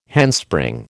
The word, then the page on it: handspring: American English